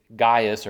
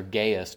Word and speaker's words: Gaius: It's Gaius or Gaius, depending on how you want to pronounce it. You know, tomato, tomato.